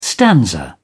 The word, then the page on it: stanza: British English